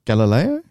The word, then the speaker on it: Galileo: Galileo? – That's the one.